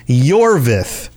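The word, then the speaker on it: Iorveth: However, one thing I must say is his name is Yorveth, pronounced Y-O-R-V-E-T-H.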